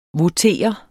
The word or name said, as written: votere